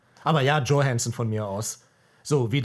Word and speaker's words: Johansson: Immer Johansson, die heißt Johansson. – Das ist halt die englische Aussprache, aber sie ist ja äh, skandinavisch äh, von ihrer Sicher?